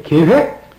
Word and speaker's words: 계획: 그의 결정 그의 계획, 그의 뜻. 우리 김명국 강도사는 이 계획이라는 발음을 하면서 밤낮 계획. 무슨 괴짝 같아요.